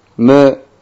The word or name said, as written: mu